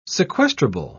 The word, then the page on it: sequestrable: British English